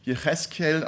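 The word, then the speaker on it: Jecheskel (יחזקאל): Und der Name Ezechiel, der auf Hebräisch Jecheskel ausgesprochen wird, der heißt, Gott will oder Gott wird stark machen.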